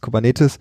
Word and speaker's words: Kubernetes: Kubernetes ist ein griechisches Wort. Ich habe damals in der Schule gelernt, äh, Altgriechisch und äh, Latein spricht man so aus, wie man es im Deutschen auch machen würde, weil niemand mehr weiß, wie man es damals ausgesprochen hat. Deswegen sage ich Kubernetes.